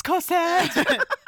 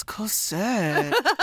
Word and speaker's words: Cosette: And the Thénardiers are like, bye, Colette. Oh, wait, Cosette. – Yeah, no, they can't get her name right. – Yeah. – It's Cosette. – Oh, darling, Colette, it's Cosette. Says Helena Bonham Carter.